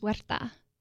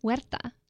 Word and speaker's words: Huerta: My last name is Huerta. – What? How do you say it? – Huerta. – Huerta? – Huerta. – Huerta. – You got to roll your R.